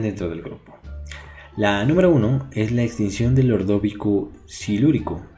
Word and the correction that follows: Ordovícico: A ver, ordovi... ordovísico, ordovísico, silúrico. El segundo se lo dije bien.